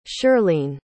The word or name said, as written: shirlene